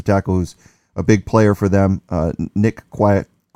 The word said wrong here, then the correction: Kwiatkowski: Kwiatkowski, I can never say his name, Kwiatkowski, the inside linebacker, he's good.